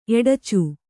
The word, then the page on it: ಎಡಚು: eḍacu